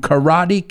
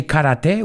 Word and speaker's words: karate: Sensei, we learned that one from Karate Kid. Ahí tenéis otra. We don't say karate, we say karate. Eso es la pronunciación americana, no digo la japonesa.